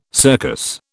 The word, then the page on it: circus: British English